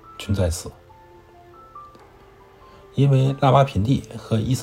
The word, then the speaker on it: 拉瓦尔品第: 中国人读的时候呢，都把它叫做拉瓦品地，那个耳基本上不发音啊。在拉瓦品地，啊，拉瓦品地位于伊斯兰堡西南十二公里，人口只有一百四十一万。